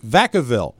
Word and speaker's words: Vacaville: I believe the town in California is pronounced Vacaville.